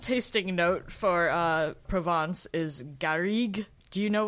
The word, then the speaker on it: Garrigue: Garrigue.